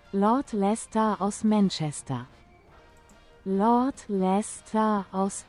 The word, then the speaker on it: Leicester: So, wie spricht man das aus? Los, komm, erzähl mal. Wie, wo ist das? Hier ist das. Los, erzähl mal. – Lord Leicester aus Manchester.